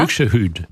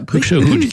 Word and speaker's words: Buxtehude: Alors j'espère que je vais pas écorcher son nom, qui est Buxtehude, c'est ça? – Buxtehude. Voilà, Buxtehude, qui était tout à fait en or vers Hambourg et tout voilà.